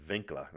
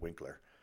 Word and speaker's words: Winkler: Our Head Start instructor was a local German woman named Frau Winkler. In German, the letter W is pronounced as a V, so her name was pronounced Winkler instead of Winkler.